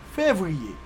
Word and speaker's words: février: Creole, février, février, février.